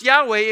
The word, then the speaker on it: Yahweh: What we know is that these are four Hebrew letters, and it's pronounced Yahweh, and in our English Bible, it is pronounced Lord, L-O-R-D, all caps.